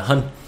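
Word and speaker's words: Hun: Người Hân này là H-O-N. H-O-N là người Hân ha. Không có chữ G nên mình không đọc là Hung mà là Hân.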